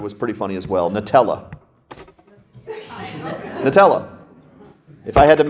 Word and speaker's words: Nutella: No, Nutella.